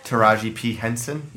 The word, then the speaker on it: Taraji P. Henson: Taraji P. Henson.